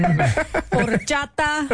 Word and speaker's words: horchata: You did great, except your pronunciation of Spanish words is lacking. Horchata.